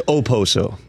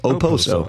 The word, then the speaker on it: Okposo: I'm told it's Okposo, by the way. – Okay. – Silent K. Which I'm not familiar with a lot of silent K's. Okposo.